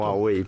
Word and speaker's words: Huawei: ¿Pronuncio bien Huawei, por cierto? – Huawei, perfectísimamente es. Bien.